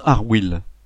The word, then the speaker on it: Arwill: Je ne sais pas pourquoi il a décidé de l'appeler Arwill. Je ne lui ai jamais demandé. On prononce Arwill, et si on veut avoir l'accent, il faut appuyer sur le « il ».